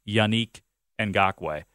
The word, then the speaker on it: Yannick Ngakoue: And by the way, we did learn that the correct pronunciation is indeed Yannick Ngakoue.